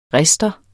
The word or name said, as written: RISTERE